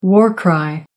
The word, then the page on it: war cry: American English